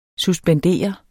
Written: suspendere

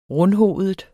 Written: rundhovedet

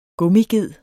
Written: gummiged

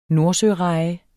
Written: nordsøreje

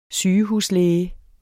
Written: sygehuslæge

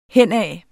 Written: henad

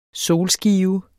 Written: solskive

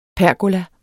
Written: PERGOLAER

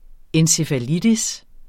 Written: encefalitis

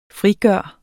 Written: frigøre

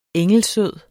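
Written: ENGELSØD

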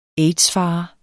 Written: aids-fare

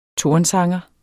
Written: tornsanger

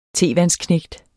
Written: tevandsknægt

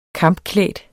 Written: kampklædt

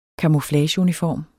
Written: CAMOUFLAGEUNIFORM

